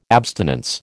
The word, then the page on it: abstinence: American English